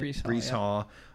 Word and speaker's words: Breece Hall: Breece Hall.